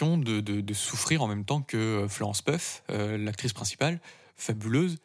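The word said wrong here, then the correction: Pugh: Piu, ça se prononce Piu.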